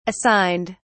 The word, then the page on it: assigned: British English